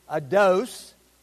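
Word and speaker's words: eido: And the word is, it's E-I-D-O, to be aware of, behold, consider, perceive. The phonetic pronunciation is ados, and it's to be aware of, behold, consider, perceive, remember, appreciate.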